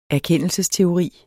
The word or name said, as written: erkendelsesteori